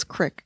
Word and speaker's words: crick: They say, they pronounce creek as crick. – They say crick? – David's nodding.